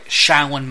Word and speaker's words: Shaolin: But Liu Kang, as we know, he is a former, I want to make sure I got this right, Shaolin monk. That's the way to pronounce it, correct? – Right.